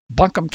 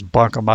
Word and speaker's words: Buncombe: I believe it's Buncombe. I've been through there, and I think I've heard it pronounced that way. But again, North Carolinians, we got thousands of them, so they can tell us. But I think it's Buncombe.